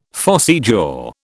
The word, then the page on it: phossy jaw: British English